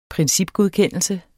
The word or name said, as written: principgodkendelse